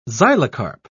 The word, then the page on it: xylocarp: British English